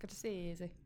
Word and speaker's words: Scorsese: It's actually pronounced Scorsese.